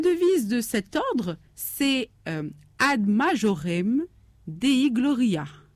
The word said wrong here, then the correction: Gloriam: J'ai bien prononcé, Maria? – Gloriam. Oui. – Gloriam, oui. Qui signifie tout pour la plus grande gloire de Dieu.